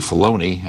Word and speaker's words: Filoni: And it's actually Filoni. I keep mispronouncing his name.